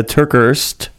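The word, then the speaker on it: TerKeurst: And so what we're talking about today is a Bible study that she's beginning about forgiveness, a book by Lisa Turkhurst. Am I saying that right at all, Pam? – I believe so.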